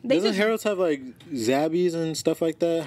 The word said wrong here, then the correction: Zaxby's: How do you say that? – Why they got egg rolls? – Zaxby's, my bad.